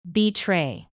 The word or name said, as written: betray